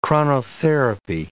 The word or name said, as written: chronotherapy